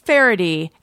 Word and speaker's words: Faherty: It's not spelled like you think, or it's pronounced differently than you'd expect from the spelling, but it is pronounced Faraday, and it is spelled F-A-H-E-R-T-Y, and I'm making a point of this so that you go to the right site.